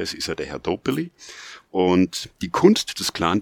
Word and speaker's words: Dobelli: Der ist oft falsch ausgesprochen, weil er da zwei L hat und dann sagen die Leute Rolf Dobelli. – Der Dobelli. – Der Dobelli.